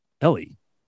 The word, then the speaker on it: Elie: So let's move on to, and I've played clips from this guy before, and I used to call him Eli because his name is spelled E-L-I-E. But I heard this lady talking to him on this clip, and his name is Ellie, which is a girl's name.